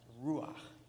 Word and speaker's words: ruach: It's the word in the Greek, pneuma, or in the Hebrew language, it's pronounced ruach.